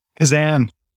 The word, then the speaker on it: Keczan: Keczan.